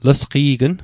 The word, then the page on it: lesqe'gn: Mi'gmaq